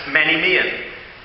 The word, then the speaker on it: menimion: The Greek word for grave is pronounced menimion, and means any visible object for preserving or recalling the memory of any person or thing.